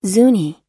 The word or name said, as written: zuni